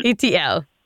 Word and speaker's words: ETL: And you pronounce E.T.L. like the letters E.T.L. You know the movie E.T.? So it's an L. – E.T.L. – Yes, perfect.